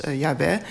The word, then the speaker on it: Yahweh: En dat idee van Marieke Ploeg, een andere naam voor God in de Hebreeuwse Bijbel is JHWH. Dus dat wordt wel uitgesproken als Yahweh.